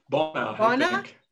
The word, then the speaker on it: Bawna: How do you pronounce B-A-W-N-A? – Bauna. – Bauna? Yeah, I'll go with Bauna.